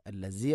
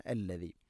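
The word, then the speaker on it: അല്ലദി (الذي): ഹുവല്ലാഹുല്ലദീ ഹുവല്ലാഹു അല്ലദി അല്ലദി, ദാലിൻ്റെ ഉച്ചാരണം ശ്രദ്ധിക്കുക. അല്ലസി അല്ല അല്ലദി.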